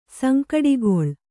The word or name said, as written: ಸಂಕಟಗೊಳ್